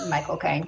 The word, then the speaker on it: Michael Caine: And they were talking about how to say his name and they say, you say like my cocaine, and it sounds like Michael Caine the way he says it.